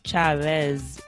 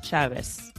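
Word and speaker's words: Chávez: The way we pronounce that is like an S. – Okay, so the Z is always the S, so it wouldn't be Chavez, it would be Chavez. – That is correct. In Spain, it will be Hugo Chavez. They can pronounce the Z in a different way. But in general, like most of the Latin American countries, it's just an S.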